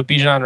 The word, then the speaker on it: Bijan: It's just like kind of a wide open tier, and I'm giving Bijan the edge just slightly. And am I pronouncing that right, Justin? – I think so. Bijan Robinson.